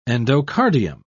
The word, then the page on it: endocardium: British English